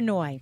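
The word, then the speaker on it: Nooyi: Indra Noy.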